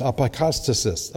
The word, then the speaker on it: apokatastasis: It's pronounced apokatastasis. Apokatastasis. Try to say that three times. Apokatastasis.